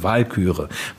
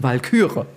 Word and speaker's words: Walküre: Sieglinde sehe ich hier gerade aus der Walküre, weil nämlich alles bei Wagner auf der ersten Silbe betont wird. Ja, also das wissen viele auch nicht. Nun, die alten Wagnerianer legen da sehr viel Wert drauf. – Ich mache es immer falsch. Ich mache nämlich immer Sieglinde und die Walküre.